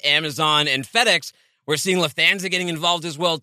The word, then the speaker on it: Lufthansa: Okay, I need to know if I'm saying this right. Lufthansa? – Lufthansa. Ooh, that was, yeah, close enough.